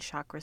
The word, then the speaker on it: chakra: We are now moving to the fifth chakra in the seven main chakra system that goes along the center line of our bodies. And this is the chakra, or chakra is really the pronunciation of it.